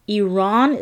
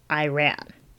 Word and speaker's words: Iran: Iran is not pronounced Iran. It's pronounced Iran.